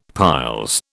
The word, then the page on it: piles: British English